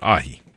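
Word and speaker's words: ají: A-J-I. That's how I'm going to pronounce it. Ahi. That's sort of how you pronounce it, I think.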